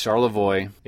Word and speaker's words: Charlevoix: We were like saying Charlevoix, but yeah, it's Charlevoix. It's French.